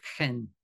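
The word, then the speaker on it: chen (חן): And when you read favor in that verse, obtains favor from the Lord, straight away you think of grace, but that would be the word chen. You know, you pronounce it as in loch.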